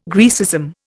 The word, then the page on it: Graecism: Indian